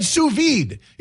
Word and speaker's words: sous vide: No, listen, I said sous vide. If you're French and know what that means, you know I nailed that pronunciation because I Googled it.